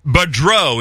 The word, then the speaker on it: Badreau: You may have to help me pronounce this one, David. – Yeah, the second prize winner, two round-trip tickets to Israel, Denise Badreau. Is that correct? – You got it. You got it. – Denise Badreau, B-A-D-R-E-A-U.